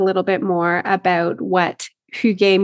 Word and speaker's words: Hygge: And I know I've seen you have written a little bit here and there about this concept of Hygge. Is that how I pronounce it? So go ahead, correct me. – It's Hygge. – Hygge. – Very good.